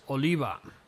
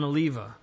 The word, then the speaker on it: Oliva: I've been mispronouncing his last name, or probably not mispronouncing it, just Americanizing it to Oliva. And the way he's pronouncing it is the way that people over here in Spain or in any Latin-based country would pronounce it, which is Oliva, John Oliva.